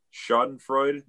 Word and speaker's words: Schadenfreude: And I suppose if you are the taking the Vikings fan approach that is highly invested in Schadenfreude this weekend, I don't know if I'm pronouncing that right.